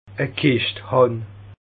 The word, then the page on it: E Kischt hàn: Bas Rhin; Schiltigheim